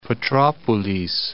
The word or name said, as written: Petropolis